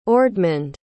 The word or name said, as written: ordmund